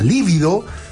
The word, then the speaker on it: libido: La libido, entre paréntesis, a menudo incorrectamente es drujulizada a la libido, así que a lo mejor es libido. Y todos le hemos dicho libido, sí. – Es como drújula, con acento en la I.